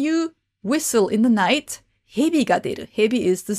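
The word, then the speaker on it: hebi (蛇): The meaning basically means that, so what you need to know is hebi is the Japanese pronunciation. The Chinese pronunciation of snake is ja.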